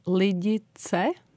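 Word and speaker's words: Lidice: So we pronounce it as Ladice. – Ladice. – Ladice, because there is the ts sound.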